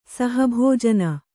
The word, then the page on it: ಸಹಭೋಜನ: saha bhōjana